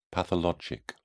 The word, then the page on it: pathologic: British English